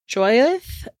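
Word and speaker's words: Jyothi: So on October 7th of 2019, Shankar killed his wife, and I believe it's pronounced Joyothi. It's J-Y-O-T-H-I, and she was 46, and their 16-year-old daughter, Jari, in their apartment in Roseville.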